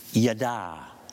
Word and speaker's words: yada: And the word for that is yada, Y-A-D-A, with an accent on the second A, Y-A-D-A, yada, yada.